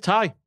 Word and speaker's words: Tay: It's Ty. You got it right the first time. – T A Y. – Yeah. Tay. – yeah, it's spelled Tay, but it said Ty.